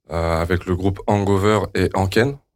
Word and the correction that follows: Ranken: J'espère que je prononce bien. – Allez, Ranken, je crois. – Ranken. – Ouais, Ranken.